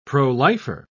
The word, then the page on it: pro-lifer: British English